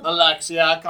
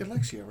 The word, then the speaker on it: Alexia: So, Alexia, did I say that right? Alexia, right? – Yes.